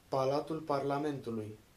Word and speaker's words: Palatul Parlamentului: Its iconic land is the massive communist era Palatul, Parlamentulu, how do you say it? Parlamentulu? – Palatul Parlamentului.